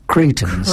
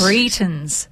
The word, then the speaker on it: Cretans: It is Cretans. – Cretans.